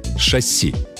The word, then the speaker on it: шасси: Шасси. Ударение в этом слове только на последний слог.